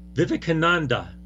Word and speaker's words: Vivekananda: His name is Swami Vivekananda. – You did it. – Good job. – Yeah. – Very, very Vivekananda.